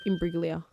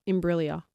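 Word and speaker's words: Imbruglia: Imbriglia. – Soft G. – Imbriglia. – Bang. – Imbriglia. Natalie Imbriglia.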